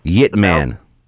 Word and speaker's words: Ip Man: would be um, Ip, uh, Ip Man. It's on Netflix. – Spell it for me. – Uh, y I P M A N. – Yip Man. – Yeah, Yip Man.